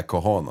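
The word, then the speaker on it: cojones: Alltså, det är kohan. Alltså. – Ja. – Kojones stavas det. – Kojones stavas det faktiskt. Mm. – Kojones. Man uttalar det som Pablo Escobar.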